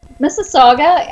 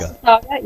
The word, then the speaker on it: Mississauga: How have you guys done up there in Mississauga? Did I say it right? – Mississauga. – Mississauga. – I'm in Mississauga, yes.